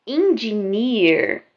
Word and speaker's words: engineer: Engineer. Olha a pronúncia comigo. Engineer. Bem no final com bastante R, tá? Significa engenheiro.